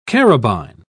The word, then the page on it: carabine: British English